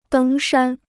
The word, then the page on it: 登山: dēng shān